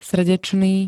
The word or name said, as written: srdečný